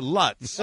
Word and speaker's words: Lutz: Lutz. Rhymes with putz, conveniently.